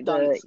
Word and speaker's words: Dunst: Uh, Kristen Dur- Durst, however you pronounce her name, uh, she's uh, like Dunst.